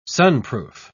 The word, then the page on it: sunproof: British English